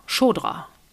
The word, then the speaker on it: Shkodra: Shkodra wird's ausgesprochen.